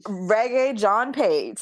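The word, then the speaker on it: Regé-Jean Page: I believe it's Reggae John Page.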